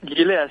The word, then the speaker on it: Gilead: Yailit se llama, o Gilead. – Gilead sí.